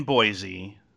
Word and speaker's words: Boise: But this is somebody in, let me see, hang on, in Boise in Idaho. – Boise. – Oh, Boise. – Yeah. It's a shame. The way I pronounced it sounded more fun.